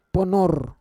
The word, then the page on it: ponor: Roon